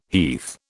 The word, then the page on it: heath: British English